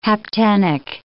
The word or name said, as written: haptenic